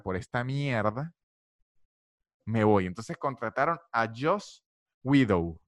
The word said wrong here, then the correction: Whedon: Widow. No sé qué forma se pronuncia. Joss Whedon. Sí, creo que es Whedon.